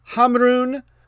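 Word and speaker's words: Ħamrun: Your matchup, number 1, Hamrun Spartans. I've been practicing my pronunciation. It sounds a little bit like Cameroon, even though it only looks like 2 syllables.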